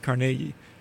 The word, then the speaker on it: Carnegie: And as a local, that's how you say it, Carnegie.